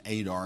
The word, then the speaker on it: Eder: It looks like Eder, but we're going to pronounce it Adar because that's how it's pronounced in Hebrew.